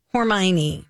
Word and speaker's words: Hermione: Oh, Hermione? – Hermione.